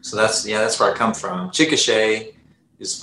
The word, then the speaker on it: Chickasha: So you grew up in Chickasha, Oklahoma. Is that right? – Yeah. Chickasha.